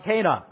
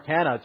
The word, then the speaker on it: Cana: we find ourselves at a Jewish wedding in the village of Cana, or Cana, depending on your pronunciation, I suppose.